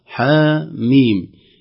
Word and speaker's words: حم: معظم الكلمات ننطق الحرف بحركته ولا ننطقه بهجائه، أما هنا كلمة حاميم لا ننطقها حم ولا حم ولا كذا، ننطقها حاميم، الحرف نفسه ينطق كاملا بهجائه.